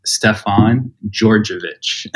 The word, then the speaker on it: Stefan Georgievich: My whole name is pronounced Stefan Georgievich. – Hell yeah. – And so if you remove the D and the J and you just add a G there, that's how you could easily pronounce my last name.